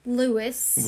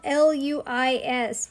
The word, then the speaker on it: Luis: He calls him Lewis the whole time, and I'm like, his name is spelled L U I S. He's from Spain. It is pronounced Luis.